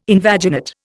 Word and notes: invaginate: Indian

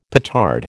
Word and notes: petard: American English

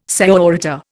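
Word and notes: señorita: Indian

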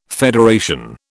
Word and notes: federation: British English

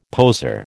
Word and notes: poser: American English

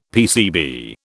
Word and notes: PCB: British English

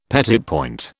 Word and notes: petit point: British English

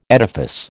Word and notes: edifice: American English

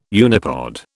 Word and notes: unipod: British English

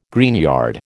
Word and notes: greenyard: American English

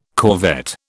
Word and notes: corvette: British English

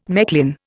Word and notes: Mechlin: Indian

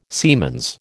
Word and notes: siemens: American English

